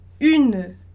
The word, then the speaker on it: une: E esse E no final, que não vai ter som, né? Não vai ser pronunciado, ele vai ter som de E, né? Ele vai ser mudo. Então, une, tá?